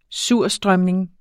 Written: surstrømming

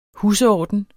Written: husorden

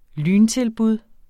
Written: lyntilbud

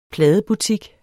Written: pladebutik

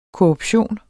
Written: korruption

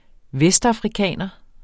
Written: vestafrikaner

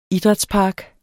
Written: idrætspark

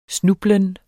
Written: snublen

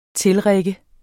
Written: tilrigge

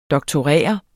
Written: doktorere

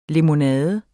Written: limonade

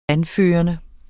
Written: vandførende

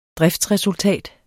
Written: driftsresultat

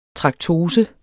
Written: traktose

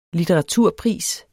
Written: litteraturpris

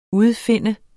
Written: udfinde

